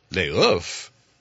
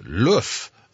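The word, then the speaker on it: L'Œuf: And he looks at my paper and he says, Luf. Oh, okay. Luf, it means the egg, and it's not pronounced Le Ouf.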